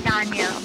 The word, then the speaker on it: Tanya: My name is but I pronounce it as T as in Tanganyika, T as in tea, the drink, but it's like, it's pronounced softly, so it's pronounced as the, so it's Tanya, but you can also say Tanya, I don't mind that.